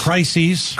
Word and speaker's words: crises: Wouldn't you call these two crises? – Yes. – Is it crises? – Crises. – Want to make sure I say it correctly.